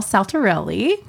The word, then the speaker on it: Saltarrelli: We have Michelle Saltarrelli.